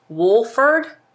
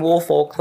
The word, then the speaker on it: Woolfolk: They say it Wolford, but it's spelled W-O-O-L-F-O-L-K. But everywhere that, like, I've read and they have the pronunciation guides. – W-O-O-L.